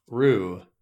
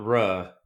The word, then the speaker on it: Ruh: To me, this is proof that Edema Rue is pronounced Rue, not Ruh, because it is a bit of cunning wordplay by Quoth to say, I think she rues the truth.